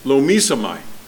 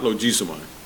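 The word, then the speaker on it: logizomai: I don't see an M in there, but the pronunciation that the program said is lomisomai, lomisomai, not logisomai, lomisomai.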